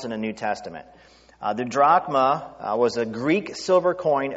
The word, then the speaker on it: drachma: Technically, it's drachme, but the right way to say it now, because it's still a coin that Greece uses today, is drachma.